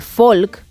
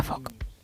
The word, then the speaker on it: folk: La pronunciación fonética correcta de folk, que bueno, puede significar gente, pueblo, el género folclórico, tiene lo siguiente. ¿Qué estás apuntando? – No. – Bien. Su regla clave es que la letra L es muda.